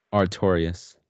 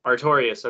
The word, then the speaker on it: Artorias: How did you say that again? Arteus? Artreus? – Artorias. – Artorias. Okay, thank you.